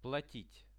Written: platit

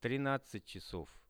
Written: 13-chasov

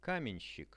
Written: kamenshik

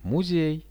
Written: muzej

